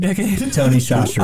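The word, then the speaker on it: Tony Chachere's: And also, I believe he blew my mind with the name of the spice he used that I've been mispronouncing for the better part of a decade. – Tony Shastri. – I call it Tony Catchers, and you're like, what the hell is Tony Catchers? – That's right, I make you a Cajun turkey.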